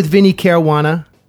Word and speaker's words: Vinnie Caruana: I'm here with Vinnie Caruana.